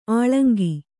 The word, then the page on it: ಅಳಂಕೆ: aḷaŋke